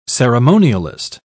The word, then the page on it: ceremonialist: British English